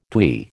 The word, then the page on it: twee: American English